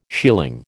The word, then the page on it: shealing: American English